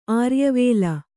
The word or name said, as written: ಆರ್ಯವೇಲ